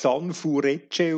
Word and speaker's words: Sanfrecce: Und offenbar, offenbar spricht man das San Furecce aus. – Oh, Entschuldigung, ja. – San steht für ähm, drei im Japanischen mhm. – und Frecce steht für italienisch Pfeile.